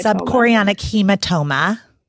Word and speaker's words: subchorionic hematoma: Subchorionic hematoma.